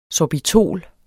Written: sorbitol

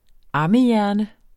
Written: ammehjerne

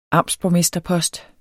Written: amtsborgmesterpost

